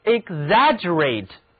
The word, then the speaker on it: exaggerate: ex 组合读作 ik，而 rate 中的字母 a 发双元音 a。好，跟着我读 exaggerate， a person who always exaggerates 就是指一个总是夸夸其谈的人。